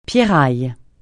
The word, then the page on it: pierraille: francais